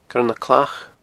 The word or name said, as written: Coire nan Clach